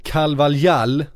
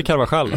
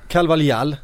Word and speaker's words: Carvalhal: Och den nya managern Carvalhal, som det ska uttalas med. – Inte Carvajal alltså. – Nej, det är, han är portugis. – Oh, då men, blir, herregud vad jobbigt. – Då blir H ett J. – Oh. – Carvalhal ska det uttalas.